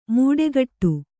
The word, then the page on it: ಮೂಟೆಕಟ್ಟು: mūṭegaṭṭu